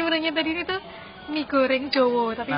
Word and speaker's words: Jawa: jadi nah yang pertama adalah tulisan Jowo mm -hmm.